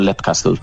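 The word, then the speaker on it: Leap Castle: Fíjate, yo diría Lip Castle en su día, Lip. Pero como Sean Ryan lo pronuncia Lep Castle, yo me quedé con Lep Castle.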